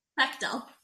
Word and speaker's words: Bechdel: The Bechdel.